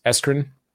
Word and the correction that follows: Erskine: Eskren. – The college. So Eskren yeah. College. So this one was built back in 1905. Erskine. Erskine. – Erskine. Yeah, you're forgetting the S.